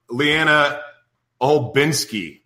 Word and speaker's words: Leanna Olbinsky: Anyway, I have an amazing guest, Leanna Olbinsky.